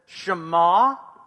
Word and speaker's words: Shema: Everyone says Shema. All right, that's how you say it.